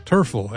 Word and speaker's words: Terfel: We have Brea back, Black 47, and Bryn Terfel. Terfel, actually, if you use the Welsh pronunciation.